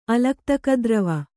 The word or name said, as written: ಅಲಕ್ತಕದ್ರವ